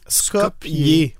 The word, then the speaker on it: Skopje: Donc, ça s'écrit S-K-O-P-J-E, mais ça se prononce Skopje.